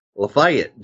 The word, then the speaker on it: Lafayette: It's like a Lafayette, Georgia. Is if you live down here in the South, it's Lafayette, Georgia.